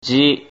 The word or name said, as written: ji